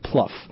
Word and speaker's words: Plouffe: It's pronounced Plouffe.